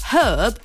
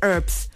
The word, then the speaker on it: herb: So apparently, Americans pronounce herb as though the H does not exist. So they are the ones who say herbs, herbs and spices, herbs and spices. British English, however, pronounce it herb.